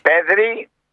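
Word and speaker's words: Πέδρι: Πέδρη, το τονίζω, Πέδρη, Κομπαρσί που είναι 18-19 χρονών, πόσο είναι.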